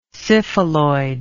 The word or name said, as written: syphiloid